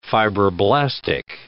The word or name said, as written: fibroblastic